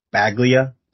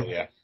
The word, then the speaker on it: Baglia: And Green, I mean, their offense is stout, but I, with Robbie Klockner and Zach, um, how you say his name, Baglia? That's how you say it. – yes, Zach, Zach Baglia. Baglia.